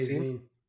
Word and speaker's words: zine: Zine. I say zine. – Yeah. Because it's a shortened version of magazine.